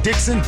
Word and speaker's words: Dixon: You can follow me on Instagram at The Real Marvin Dixon. Dixon is spelled D-I-X-O-N.